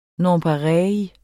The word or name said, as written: nonpareille